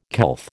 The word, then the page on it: couth: American English